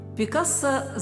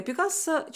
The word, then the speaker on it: Пикассо: Поэтому испанское Пикассо часто произносят на французский манер с ударением на последний слог – между прочим, это сокращенный вариант имени, но об этом чуть позже.